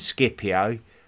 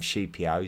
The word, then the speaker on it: Scipio: who I know a lot of people call him Scipio, um, I'm just trying to stay true to the Latin pronunciation and, and call him Scipio.